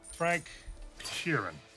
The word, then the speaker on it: Frank Sheeran: Frank Sheeran. I said that right? – Yeah, you said right.